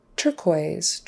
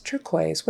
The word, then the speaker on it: turquoise: But then we have words like conquer, conquer, U is silent, turquoise, turquoise, what a pretty turquoise ring you have. Again, the U is silent. Racket, racket, silent U, liquor, and lacquer, both silent U. Now, some words have Q-U-E on the end, and the U and the E are silent.